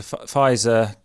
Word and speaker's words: Pfizer: Tried to think of a joke about the Pfizer one, about something about the Pfizer comes with a silent P.